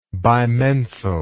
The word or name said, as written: bimensal